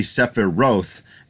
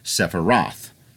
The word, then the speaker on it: Sephiroth: my study of Japanese says it should be Sephiroth, and again, that's another one that we've always pronounced that way and is now in canon because they have voice actors in Advent Children and a number of other things with Final Fantasy VII. The American pronunciation is Sephiroth.